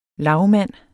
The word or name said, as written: lagmand